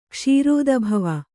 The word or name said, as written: ಕ್ಷೀರೋದಭವ